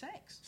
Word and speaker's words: CeX: It's pronounced sex. – Sex. – That's pronounced sex.